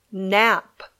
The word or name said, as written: nap